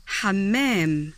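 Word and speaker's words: حمّام: Şimdi dinleyeceğiniz iki isimden ikincisinde M harfi şeddeli yani çift telaffuz edilmektedir. – Hamam. – Güvercin anlamında. – Hamam.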